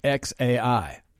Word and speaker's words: xAI: She also told a user on Instagram that the name pronounced X and then AI. So the name is XAI.